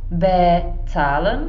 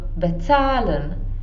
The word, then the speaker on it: bezahlen: Takže třeba není to bezahlen, ale je to bezahlen, jo, ten důraz je až na tom ca.